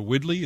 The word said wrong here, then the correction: Wildey: is it pronounced Widley? – Who? – The artist. – Wildey. – Wildey.